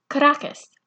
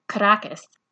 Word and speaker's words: Caracas: For example, the capital city is Caracas. Only outsiders call it Caracas. Insiders pronounce it Caraca.